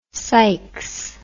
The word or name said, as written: Sikes